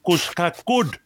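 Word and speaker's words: Couacaud: Och eh, i andra omgången faktiskt mot en fransos som heter Enzo Kouchkakoud. Det eh, var ett bra franskt uttal Ranking 190.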